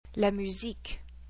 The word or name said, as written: La musique